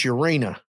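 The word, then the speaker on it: Ureña: Yep, against Urena. Yep. I believe that's how you say it. – Yep.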